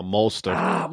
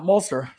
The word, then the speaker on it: Mostert: You're not looking for just a Kittle or just Emmanuel Sanders or just a what's the name of the running back, Mossett, Mostert. – No, Mostert. – Mostert. Sorry. Didn't mean to butcher that name.